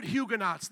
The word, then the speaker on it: Huguenot: Put Huguenots there for me. Huguenots, like astronaut, Huguenot. But it's actually, I believe, spelled with an OT at the end.